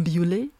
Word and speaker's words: Biolay: Aber ich habe da auch einen Sänger kennengelernt, nennt sich ähm, schreibt sich Benjamin Biolay, äh, französisch ausgesprochen natürlich Benjamin Biolay.